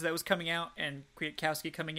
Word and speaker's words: Kwiatkowski: I will say that linebacker Nick Kwiatkowski, speaking of butchering names, it's spelled Koski, but it's Kowski, so just bear with me there. Kwiatkowski was getting rotated in a lot, but he was subbing with Michael Walker, not Rayshon Evans, which was surprising to me.